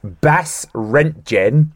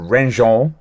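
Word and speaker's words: Basse-Rentgen: Rich, my route would start on the small French town of, again, pronunciation issue coming up here, Bas-Rentgen, or Rengen, Bas-Rentgen.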